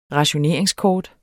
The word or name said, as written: rationeringskort